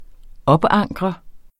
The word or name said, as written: OPANKRET